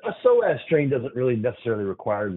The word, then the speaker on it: psoas: We're saying psoas, but if our listeners are looking it up, it begins with a silent P. It's P-S-O-A-S, the psoas muscle.